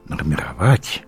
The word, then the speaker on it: нормировать: Я помню, как-то мы с ней говорили, беседовали, и я ей сказал нормировать. Александр Александрович, ну как, нормировать? Она точно знала классическое московское произношение, классическое московское ударение.